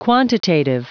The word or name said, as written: quantitative